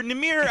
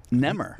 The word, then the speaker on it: Nemr: You want to pronounce it properly, which makes it a disaster. But yeah, the closest would be like Nemr.